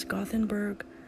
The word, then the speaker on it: Gothenburg: Yes, Gothenburg, Västra Götaland, Sweden.